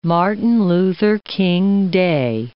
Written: Martin Luther King Day

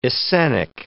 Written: Essenic

